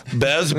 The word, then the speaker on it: Bez: How do you say your last name? – Bez, B-E-Z. That should be pretty easy.